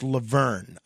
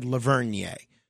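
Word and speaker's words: La Vergne: It's pronounced Laverne. I guess I said Lavernier.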